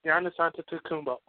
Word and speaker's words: Giannis Antetokounmpo: I've said it right all day practicing because I knew I was going to bring up his name. And I screwed it up there. Giannis Antetokounmpo. There we go.